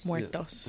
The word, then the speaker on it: Muertos: It was for Dios de los Muertes. I said that wrong, but it's okay. Uh, Dia de los Muertes. Muertos. Yeah, muertos. Sorry. Oh, muertos.